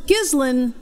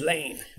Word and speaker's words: Ghislaine: It's pronounced Ghislaine as far as I've been able to... – Oh, I thought it was Ghislaine. – Well, it's... – If you're Lionel, it's Ghislaine. – Ghislaine is how it looks, but Ghislaine, apparently.